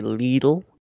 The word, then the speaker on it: Lidl: Hey, how do you say it? Little, is it? Is that how you say it, or is it Lidl? – I say little, but all the ads on the radio say Lidl, and it just bugs me.